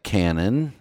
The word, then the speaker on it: canon: The canon is pronounced like cannon, so cannon, and poshelbel is as in chutzpah, so poshelbel.